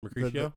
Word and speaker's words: Mercutio: And then Matt Tagger was Mercutio. I think is how you pronounce it. – Mercutio.